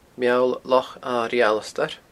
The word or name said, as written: Meall Loch Airigh Alasdair